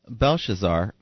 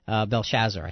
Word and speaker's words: Belshazzar: Belshazzar, I think, is the way to pronounce it.